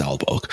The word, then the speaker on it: Aalborg: I'm sorry if I pronounced that wrong, but it's Aalborg.